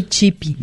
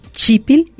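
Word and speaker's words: chípil: Muy bien, entonces no se olviden de agregar la L, chipil, y el acento en la primera I.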